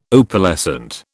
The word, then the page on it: Opalescent: British English